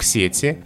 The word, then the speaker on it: сети: В словарях нормы такие. Если мы говорим о косвенных падежах, то на первом слоге нет сети. Подключиться к чему? К сети. И только в предложенном падеже падеже допустимы варианты в сети и в сети. А во множественном числе ударение на окончание сетей, сетями, сетях, там сложности нет.